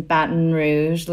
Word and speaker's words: Baton Rouge: I know you were born and raised in Baton Rouge, Louisiana.